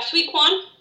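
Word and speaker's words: Zui Quan: How do you pronounce Z-U-I? – It's Shui? – Shui? Yeah, Shui Quan.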